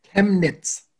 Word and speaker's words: Chemnitz: It's C-H-E-M-N-I-T-Z. – Chemnitz.